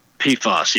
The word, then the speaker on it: PFAS: And the main forever chemical that they mentioned in the EPA report last week, and I'm probably going to mispronounce it, but the acronym is PFAS? – PFAS, yep.